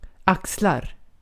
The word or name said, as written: axlar